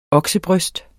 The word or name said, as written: oksebryst